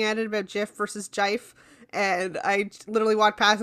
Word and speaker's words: GIF: Everyone knows the actually correct pronunciation is jife.